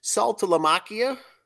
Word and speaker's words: Saltalamacchia: So is this the Saltalamacchia? – Yes. – Is that how you say it? That's the longest. – I think that, that's it right there.